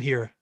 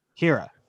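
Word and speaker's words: Hiura: How do you pronounce his last name? I'm sorry. – Who? – The second baseman moving to first. – Keston Hira. – Hira.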